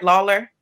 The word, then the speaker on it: Lawler: Lawler? – That's correct.